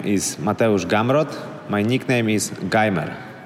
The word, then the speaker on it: Gamrot: So you got Gamrot correct.